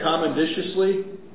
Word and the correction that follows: commodiously: Commodiously? – Commodiously. Yeah, that's a great.